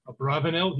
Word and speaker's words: Abravanel: I saw that this question is raised by Don Yitzhak, Rabbi Don Yitzhak Abarbanel, by the Abarbanel, or probably more properly, his name should be pronounced Abravanel. He has descendants today who very proudly pronounce the name Abravanel.